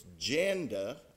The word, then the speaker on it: Janda: Her name rhymes with panda. It's Janda. I had never heard that name before. She's the one, though, that said it rhymed with panda.